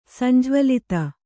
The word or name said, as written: ಸಂಜ್ವಲಿತ